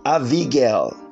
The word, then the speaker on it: Abigail: Abigail, that's the Hebrew pronunciation.